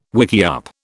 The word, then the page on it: wickiup: British English